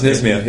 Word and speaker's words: NSMIA: Did you pronounce it NISMIA? NSMIA? – Yes, it was NISMIA.